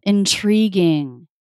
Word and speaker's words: intriguing: Intriguing. Intriguing CH sound. – Totally CH. – Yeah. So is your mind blown? Have you thought about the fact that you are pronouncing the word intriguing with a CH? – It's pretty shockingly not the way it's spelled.